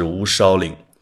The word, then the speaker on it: 乌鞘岭: 这个字读梢啊，边梢的梢，它是一个多音字，在这里是乌梢岭。